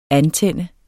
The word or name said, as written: antænde